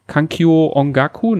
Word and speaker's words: Kankyo Ongaku: Kankyo Ongaku, ne? So heißt er, habe ich ja. richtig ausgesprochen, Sven? Ja.